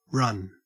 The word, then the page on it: run: Australian English